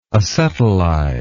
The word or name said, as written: acetylize